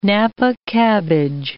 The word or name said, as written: napa cabbage